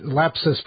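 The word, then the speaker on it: Lapsus$: It's L-A-P-S-U-S dollar sign. How do you pronounce it? It's Lapsus.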